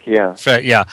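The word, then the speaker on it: Frelich: The thing I remember, it was such a wonderful show with Phyllis Frelick. Is that how you say it? – Frelick, yeah.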